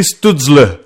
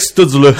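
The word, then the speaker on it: Stützle: Oui, mais nous autres, on m'a appris Stoudzle. Fait que, bon, on va y aller avec Stoudzle.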